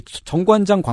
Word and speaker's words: 정관장: 예, 그 정관장 광고 나올 때 보면 네. 음, 증관장이라고 발음하거든요.